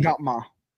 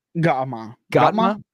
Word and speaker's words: Gautama: So, do you know how to pronounce his like proper first name? – Let me read it and give it a try. – Gautama the Buddha, 563 to 483 BC. – Gautama. – Gautama. Gautama. Okay, I'll trust you.